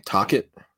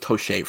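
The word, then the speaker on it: Tocchet: Tockett. – Yeah. How do you not know how to say that name? – Bro, I thought it was Toshay for like the longest time.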